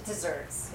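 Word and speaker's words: deserts: The proper spelling of dessert in this case is D-E-S-E-R-T. It's a phrase that uses an archaic word, dessert, spelled like desert, but pronounced like dessert.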